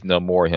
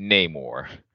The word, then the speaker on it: Namor: And there's not a spoiler, but not exactly a spoiler, but the thing is, the only person that pronounces Namor's name right in that film is Namor himself. Everyone else pronounces it Namor. And I was like, that just how it feels when you're like, America is like, because we're used to pronouncing it Namor, and then you're telling me that this guy who is like Aztec, you know, kind of Mayan, whatnot, and he give you the right pronunciation of his name, and we're just like, nah, we like the sound of Namor better.